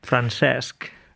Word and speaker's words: Francesc: Me ha gustado ahí tu pronunciación del catalán, ¿eh? – Francesc. Es difícil, ¿eh? No estaba seguro, pero bueno, tú lo apruebas, ¿no? Tú sabes catalán, lo he pronunciado bien. Lo has pronunciado muy bien.